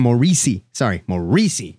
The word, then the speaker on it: Morrissey: Morrissey. You got to get the... – You know, yeah, yeah. You have to put... – The emphasis. – On the right syllable.